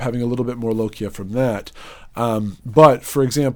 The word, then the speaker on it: lochia: or more of the lochia? Am I saying that correctly? – Lochia is the right word.